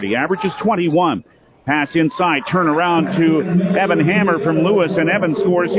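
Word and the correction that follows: Hamre: Hamry is how the young man pronounces his name.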